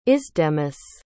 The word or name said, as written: isdemus